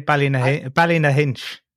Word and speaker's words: Ballynahinch: Ballynahinch. – Ballynahinch. Okay, I have to get like the song in there. Like, I don't like, I don't do that. But she's taken to the Drumcura Forest near Ballynahinch and is abandoned.